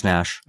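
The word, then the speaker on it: Naish: Carroll Nash, or Nash. There's an I in there. – Yeah, but it is pronounced Nash.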